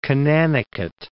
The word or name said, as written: Conanicut Island